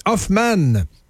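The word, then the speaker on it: Hofman: Moi j'ai appris qu'il fallait le prononcer comme ça, Hoffman, vous n'avez pas compris que cet être est un fucking génie.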